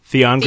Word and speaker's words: Theon: Theron of Greyjoy. Did I say his name correctly? – Theon Greyjoy? – Oh, Theon Greyjoy? – Theon Greyjoy, yes.